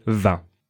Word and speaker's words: vingt: Yes, and this is an important thing to know, because when you will count from 21 to 29, you will have to pronounce that T. – Right, but when you just say 20, you don't. You just say vingt.